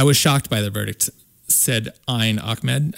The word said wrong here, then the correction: Ani Ahmed: Am I saying that right? – Ani Ahmed. – Ani Ahmed, thank you.